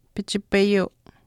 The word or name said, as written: pichipayuu